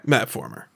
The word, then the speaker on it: Mapformer: It rhymes with platformer. So that's my word, right? – Okay. – Mapformer.